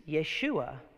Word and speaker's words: Yeshua: But if you take that name and you bring it into the borders of Israel and say it in Hebrew, it's Yeshua.